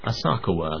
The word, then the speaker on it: Asakawa: Now, I'd never heard of this chap, this Asakawa, I think his name is, that's how it's pronounced.